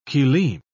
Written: kilim

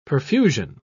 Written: perfusion

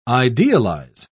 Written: idealize

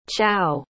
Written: chao

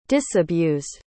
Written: disabused

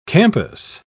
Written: campus

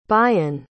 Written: bayen

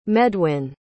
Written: medwin

